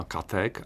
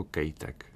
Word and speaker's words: Katek: To jméno se poameričtilo na Katek, ale já si myslím, že američané nebo ve Spojených státech to jistě bylo vyslováno jako Kejtek. – Nicméně v Praze myslím, že typicky Katek asi říkali.